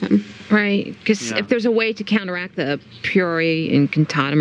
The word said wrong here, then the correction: Priori Incantatum: Priori Incantatum is the spell that makes you see your dead parents, right? – That's right.